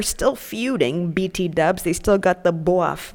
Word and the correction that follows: beef: That's just beef. I don't know why I pronounced it like that.